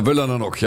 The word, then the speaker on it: Völlanernock: Der Völlanernock, ja. Nur ich muss. Völlanernock. Nur ich habe gesagt Völlaner. – Ja, hätte ich auch gesagt. – Ja, Völlaner. Ja, Völlanernock. Stress is on the first syllable.